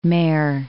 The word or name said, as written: Mare Island